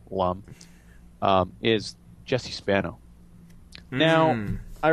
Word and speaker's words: Lum: Apparently, my research is telling me that it's pronounced Lum, but you can continue. – Is it Lum? I assume it was Loom.